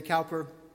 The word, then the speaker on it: Cowper: Actually, people in his day would have pronounced it William Cooper, but since we have one of those in our congregation, I go the way people often pronounce it today, Cowper.